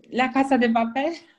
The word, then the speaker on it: La Casa de Papel: Oh, first La Casa de Papel, or how, I don't know if I pronounced that.